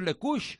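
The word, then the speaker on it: Lecoeuche: No, la E también se pronuncia Lecouche.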